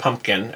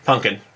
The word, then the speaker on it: pumpkin: I didn't find out until I was, like, 30 that pumpkin is pronounced pumpkin and not punkin.